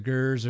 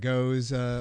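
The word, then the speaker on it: Gueuze: The Goose or the Gers or the Goes, however, whichever pronunciation you've heard or you those are rare.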